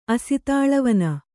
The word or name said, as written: ಅಸಿತಾಳವನ